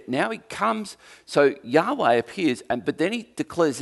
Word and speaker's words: Yahweh: Anyway, so this name, Lord, is Y-H-W-H in English. And the closest we can come to figuring out how it's pronounced is Yahweh. Or if you don't want to, you can go Yahweh.